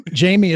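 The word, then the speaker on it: Jamy: but most people spell my name wrong, and my Jamie is spelled differently. It's J-A-M-Y.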